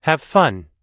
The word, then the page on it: have fun: American English